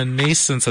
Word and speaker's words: Nascence: Am I pronouncing Nascence Advisory correctly? – Very well.